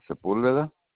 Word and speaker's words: Sepulveda: Santiago Sepulveda. – Spell that last name. – S-E-P. Okay, let me start all over again. S-E-P-U-L.